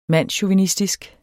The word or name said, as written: mandschauvinistisk